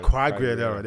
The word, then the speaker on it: Quagliarella: I have a champ for, I always butcher his name, Fabio Quagliarella. – Who? Oh, Quagliarella. – Quagliarella. – Quagliarella, there we go.